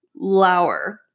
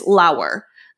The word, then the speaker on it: Lauer: I asked her what her name meant, and she goes, my mom's name was Laura, so she named me Lauer. Rhymes with flower. – Okay. – So I'm like, okay. It looks like lower. And she goes, well, it's not. It's Lauer.